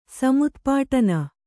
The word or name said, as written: ಸಮುತ್ಪಾಟನ